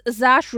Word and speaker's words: Saoirse: Sawas Ronan, also, wie auch immer. – Ich glaube, es ist Saoirse Ronan und ich weiß, dass sie in jedem Interview ihren Namen aussprechen muss.